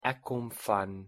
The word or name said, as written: eu sou